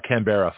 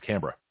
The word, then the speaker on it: Canberra: So when you get to Canberra, it's not Canberra, folks. Okay, it's Canberra.